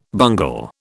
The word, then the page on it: bungle: British English